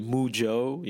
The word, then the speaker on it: Moojo: Like in the US, they say Moojo, you know, like for the cow and stuff, so but it's quite funny, but this is where it comes from.